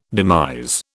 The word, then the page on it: demise: British English